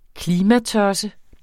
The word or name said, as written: klimatosse